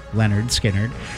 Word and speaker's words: Lynyrd Skynyrd: And we're dialing up 1973, 53 years ago, when Lynyrd Skynyrd released their self-titled debut album, pronounced Lynyrd Skynyrd,